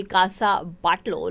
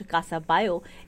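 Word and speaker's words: Casa Batlló: And कासाबायो को actually अगर लिखा जाएगा तो ऐसी कुछ प्रोनाउंसिएशन होगी कि कासा बाटलो, लेकिन इट्स प्रोनाउंस्ड कासाबायो.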